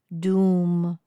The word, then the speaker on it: Dum: If you ever feel in need of protection during any situation, Dum, which is D-U-M, but pronounced Dum, will help.